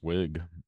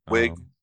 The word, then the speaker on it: Wiig: So there's a scene where, Kristen Wiig's character is, am I saying her name right? I've never heard it. – I think it's just Wig. – Wig. Yeah. I always think the two I's mean that you should stretch the sound out to an E.